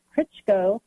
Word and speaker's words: Krichko: My last name is spelled Krichko, KRICHKO.